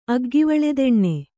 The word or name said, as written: ಅಗ್ಗಿವಳೆದೆಣ್ಣೆ